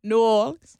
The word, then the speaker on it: New Orleans: But if you're from New Orleans, you probably say it like this. – New Orleans.